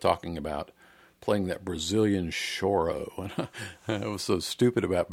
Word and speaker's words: choro: I thought it was pronounced coro because, you know, when I was in high school, there was a chorus. We pronounce it chorus.